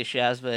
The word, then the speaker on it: Shazma: People around here will just say Shazma anyways, so that's how I spell it.